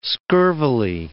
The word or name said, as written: scurvily